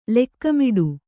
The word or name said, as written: ಲೋಕಮೂಢ